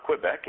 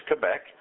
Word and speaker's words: Quebec: I at one time was taking a trip up into Canada, in Quebec, Canada, and that's the right way to pronounce that. It's not Quebec, it's Quebec.